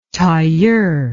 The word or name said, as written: tailleur